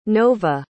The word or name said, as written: novah